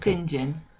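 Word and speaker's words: St John: It's Stingin. It's pronounced that, but it's written STJOHN.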